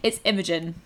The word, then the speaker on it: Imogen: It's Imogen. – Imogen. Okay, yeah. I love it. – Yeah. It's one of those ones which, because I think the American pronunciation is Imogen.